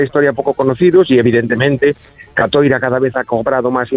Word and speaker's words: Catoira: Catoira, porque Francisco basó su novela, la novela Sur, en precisamente en Catoira, los embarcos de los vikingos por esa zona de Galicia.